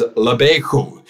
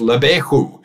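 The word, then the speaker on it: levecha: The pronunciation of that word heart, or your heart in Hebrew, is lebechu. And thank you to our Orthodox Jewish friends who have helped me try to be able to pronounce that well, but it's lebechu.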